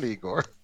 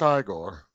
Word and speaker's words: Igor: It's not Igor. That's what you always think of." – He didn't have the hunchback, though. – Hmm. – You think of that, it's pronounced Igor.